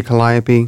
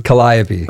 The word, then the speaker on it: Calliope: Am I still saying that right? – I like people to say it however they want. Okay. I say Calliope. – Calliope.